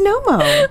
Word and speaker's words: Knomo: Oh, did you see that Knomo bag? – Knomo? I think it's Nomo.